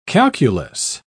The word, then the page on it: calculous: British English